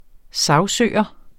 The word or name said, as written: sagsøger